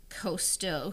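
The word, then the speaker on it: Cousteau: I always get Costo and Cocktail mixed up, but I think it's Costo who was the French, underwater explorer.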